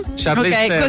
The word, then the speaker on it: Charlize Theron: E mica c'è una persona che si chiama, leggo testualmente come se non lo sapessi pronunciare, Charlize Theron. – Charlize Theron, così.